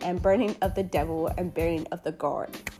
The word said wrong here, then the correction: gourd: Gourd. I think I pronounced that right. G-O-U-R-D. Okay.